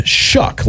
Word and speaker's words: Shough: It's spelled S-H-O-U-G-H, which somehow results in shuck, like shucking corn. – Yeah. – S-H-U-C-K is how you would pronounce S-H-O-U-G-H.